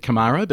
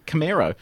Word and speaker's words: Camaro: Aussie, I said Camaro, but it's apparently Camaro.